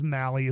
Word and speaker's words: Mahle: Just so you know, it is Mahle is the way you pronounce M-A-H-L-E. I'm horrible with pronunciations, but it is Mahle when you're looking at his name.